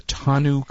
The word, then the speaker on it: tonneau: So it's a hard to know TONNEAU, I don't know how to pronounce it, cover, okay. – that deploys automatically. – Tonneau. They used to call that a TONNEAU cover.